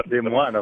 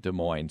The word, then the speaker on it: Des Moines: And I will tell you, the only thing that we don't understand is the way you pronounce Des Moines, the Des Moines. And in French, we say Des Moines, of course.